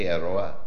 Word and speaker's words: aroha: we called it Initiative Te Aroha Airahi. Airahi. So aloha is the Hawaiian, we always say aloha, but aroha is how Tahitians pronounce it, and it means love.